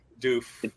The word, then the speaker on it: Doof: And I remember when they first showed that guy the how do you, how do you say his name the Doku or Doof Doof. – Doof.